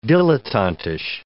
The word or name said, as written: dilettantish